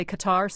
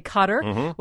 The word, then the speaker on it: Qatar: And I'm going to give you two pronunciations here. Some say Qatar, some say Qatar will host it in 2022.